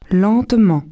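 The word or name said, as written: lentement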